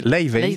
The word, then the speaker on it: Laufey: Elle est islandaise, Leivey. Ça se dit comme ça ? – Ça se dit Leivey ? – Leivey. Ah. – Ça s'écrit L-A-U-F-E-Y, Leivey.